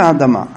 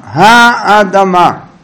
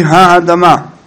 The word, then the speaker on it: האדמה: מה זה האדמה? האדמה, הישמה, בקמץ, בורא פרי האדמה, בורא פרי העץ,